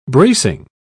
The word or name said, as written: bracing